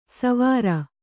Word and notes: ಸೌವೀರ: Kannada

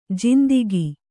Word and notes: ಜಿಂದಿಗೆ: jindige

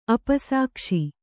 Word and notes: ಅಪಸಾಕ್ಷಿ: apasākṣi

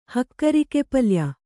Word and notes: ಹಕ್ಕರಿಕೆಪಲ್ಯ: Kannada

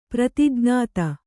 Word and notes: ಪ್ರತಿಜ್ಞಾತ: prati jñāta

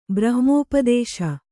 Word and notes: ಬ್ರಹ್ಮೋಪದೇಶ: brahmōpadēśa

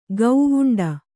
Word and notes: ಗವುಂಡಿ: gavuṇḍi

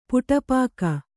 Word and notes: ಪುಟಪಾಕ: Kannada; puṭa pāka

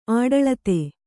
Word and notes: ಆಡಳಿತೆ: āḍaḷite

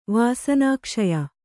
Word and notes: ವಾಸನಾಕ್ಷಯ: vāsanākṣaya

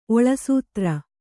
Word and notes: ಒಳಸೂತ್ರ: kannada

